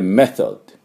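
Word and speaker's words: method: でメソッド、メソッドっていうふうに発音します。最後 D ですから軽くデッって音をつけておいてください。メソッドって O をつけてはいけません。デッっていうだけです。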